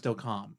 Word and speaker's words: Delcambre: And then we're going to talk about, it's spelled Delcambre, Louisiana, but I think it's actually pronounced Delcambre. I don't know. That's the way they pronounced it on the History Channel. So I'm going with Delcambre because that's the way they said it on there.